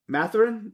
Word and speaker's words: Mathurin: You know, they've got a good young player in Benedict Mathurin.